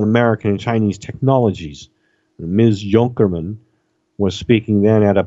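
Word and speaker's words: Junkermann: And the last name is spelled J-U-N-K-E-R, Junker, M-A-N-N, Mun. Junkerman. Junkerman.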